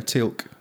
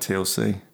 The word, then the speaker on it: Teal'c: Is it actually pronounced Teal'c? – Yeah, Teal'c. Yeah. – Oh, I thought it was like TLC. That's the reasonable assumption to make.